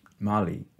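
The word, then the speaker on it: MoLI: Mali is pronounced Mali in a nod to Molly Bloom, and multimedia displays and inclusive exhibitions keep things relevant and zippy.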